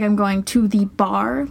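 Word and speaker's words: barre: And I've been doing the bar workout. No, it's not pronounced bar-a. And I've been going every day since April 1st. – That's good to know, because I definitely read it bar-a.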